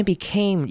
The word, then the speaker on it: became: 这边 became 要特别注意， became 后面的 a 要记得念成长母音 a 的声音，要发把 a 的声音都咬出来。